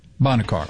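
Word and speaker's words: Bonnet Carré: And so they open up is it the Boncari something? Is that the right pronunciation? – Bonnet Car.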